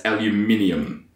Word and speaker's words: aluminium: That's aluminium. That's how you say it. Aluminium.